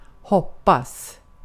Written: hoppas

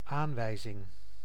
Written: aanwijzing

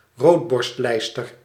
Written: robin

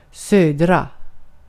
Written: södra

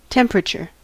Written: temperature

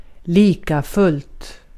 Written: likafullt

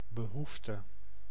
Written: besoin

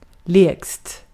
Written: lägst

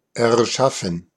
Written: erschaffen

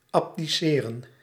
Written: abdicate

